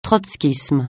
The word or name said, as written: trotskisme